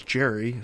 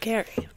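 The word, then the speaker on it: Gerry: The guy's name was Jerry, so you attached Jerry to salamander. – It's actually pronounced Gary.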